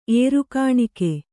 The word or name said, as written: ಏರುಕಾಣಿಕೆ